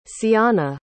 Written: ceana

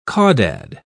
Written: caudad

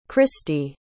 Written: kristy

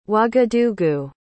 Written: ouagadougou

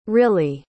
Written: rylee

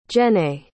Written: jenné

